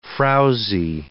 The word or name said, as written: frowsy